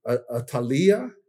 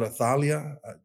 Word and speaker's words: Athaliah: And so all of his brothers he killed off, and he was a husband of Athaliah, is the right way of saying. It's not Athaliah. Just being Mexican-American, I thought Athaliah was easier, but it's Athaliah is the Hebrew pronunciation.